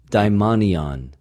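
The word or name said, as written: δαιμονιον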